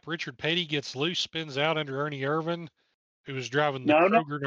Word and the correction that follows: Irvan: No, that's Ernie Irvan. Irvan. – Oops. Oh, yeah, they don't know how to say his name yet. It's Ernie Irvan.